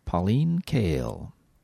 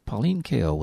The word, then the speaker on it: Pauline Kael: Pauline Kael. Pauline Kael was a film critic who wrote for The New Yorker magazine. Pauline Kael.